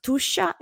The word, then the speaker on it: Tuscia: This is pronounced Tuscia, and Tuscia is precisely this territory, this region south of Tuscany in Italy, where I live now, between Florence and Rome.